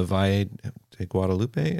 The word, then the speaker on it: Valle de Guadalupe: but from the Valle de Guadalupe, is that how you say it? – Yeah.